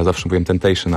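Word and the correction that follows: XXXTentacion: ale to jest Tentacion.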